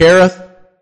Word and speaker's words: Kerith: And the reason why I think it's funny that it's pronounced Kerith is because God's going to care for him completely.